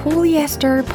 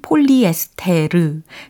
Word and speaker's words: polyester: 네, 폴리에스터, 폴리에스터 굉장히 자주 들렸습니다. 우리가 쓰는 폴리에스테르 그것을 이제 폴리에스터 이렇게 영어식으로 발음을 하시면 되는 거고요.